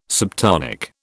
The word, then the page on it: subtonic: British English